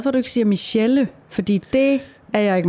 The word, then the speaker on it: Michèle: Altså den, den, udtale, den gør jo så, at man udtaler edet som et æ, for ellers ville det være Michelle, hvis ikke der var noget over.